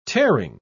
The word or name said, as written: tearing